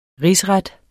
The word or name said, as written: risret